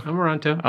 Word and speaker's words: Amoranto: Amoranto.